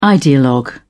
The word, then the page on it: ideologue: British English